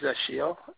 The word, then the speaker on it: Ashil: My name is Ashiel, A-S-H-I-L. – A-S-H-E-I-L, Ashiel? – No, I-L, yes.